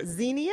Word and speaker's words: Xenia: Xenia, is that how you say it? – Yeah, that's actually just north of here.